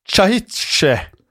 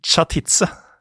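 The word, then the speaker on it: Čachtice: Det en del, og bryllupsgavene her til Báthory var, og da må du hjelpe meg her, jeg uttaler det som chahitse. – Jeg uttaler det som chatitse.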